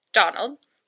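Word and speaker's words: Dónal: It's D-O with an accent, N-A-L, but it's pronounced... – Okay. – It's an accent aigu on the O. I don't know how to say that in English. It's a... – It's just like, what kind of accent? – It's a right-facing dash on top of the O. – Okay. I see. – So it's... – Donald. – I don't know. – Donald. – So it's Donald. So, like I said, we're using the English pronunciation.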